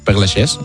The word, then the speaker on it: Père Lachaise: No hay autopsia, fueron 5 personas solo al entierro en el cementerio de Père Lachaise, creo que se pronuncia. – Père Lachaise, merci.